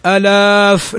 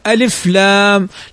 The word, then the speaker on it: ألف: وهناك حرف لا يمد أصلا إنما ينطق به هكذا وهو الألف، فنقول ألف لام، ما نقول ألاف، لا، ألف لام.